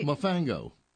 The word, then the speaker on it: Mofongo: Let's go back to John and Jason, Brooklyn Pint, and Mofongo. – Was that right? Did he say it right? – Mofongo. – Are you? Yeah, you're saying it right.